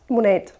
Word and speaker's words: Monet: Monet, spricht man die aus? – Monet. – Monet. Mhm. Monet. Okay, da hätte ich ein stummes E am Ende vermutet, aber gut.